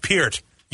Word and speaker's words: Peart: Lisa, if you were a bigger fan of Rush, you would know his last name is pronounced Peart.